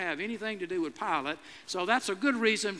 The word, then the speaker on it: Pilate: Of course, I could have said to them, you know, I'm kind of a religious scholar, and I know you don't pronounce it Pilate, you pronounce it Pilate.